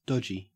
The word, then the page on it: dodgy: Australian English